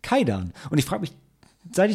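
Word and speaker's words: Kwaidan: Also ich glaube, es ist wirklich Kaidan und das übersetzt sich als Geistergeschichte oder Gruselgeschichte.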